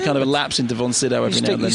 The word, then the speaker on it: von Sydow: And I once met him, and I said, how do you pronounce your name? And he said, well, how do you pronounce it? And I said, well, von Sydow. He said, well, officially, it's von Sydow.